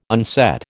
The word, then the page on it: unset: American English